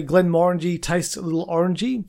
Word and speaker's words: Glenmorangie: Yeah, because like there's you know, some people say Glen Morangi and stuff like that, but let's find what the actual way of pronouncing it is. – Hopefully we're right. – Glen Morangi.